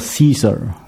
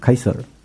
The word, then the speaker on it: Caesar: 凯撒大帝的名字叫 Gaius Julius Caesar，你知道吗？这是凯撒大帝的名字。这个名字好几个都有的都出现在圣经中某一些人物的名字里面。这个他的姓叫 Caesar，是这样拼的，英文都读作 Caesar 啊。可是我们为什么翻译成凯撒呢？因为他拉丁文的读法叫 Caesar，是吧？凯撒，有没有听懂我在说什么？